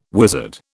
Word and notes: wizard: British English

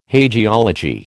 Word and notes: hagiology: American English

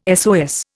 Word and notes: SOS: Indian

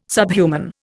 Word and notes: subhuman: Indian

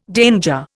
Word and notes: danger: Indian

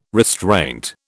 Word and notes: restraint: British English

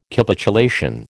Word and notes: Capitulation: American English

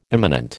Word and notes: imminent: American English

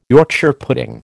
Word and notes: Yorkshire pudding: American English

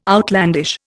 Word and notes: outlandish: Indian

